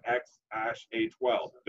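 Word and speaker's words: X Æ A-XII: So the pronunciation goes like this. X like the letter, AE. It's pronounced ash, and then your standard A-12 as in X ash A-12. Duh.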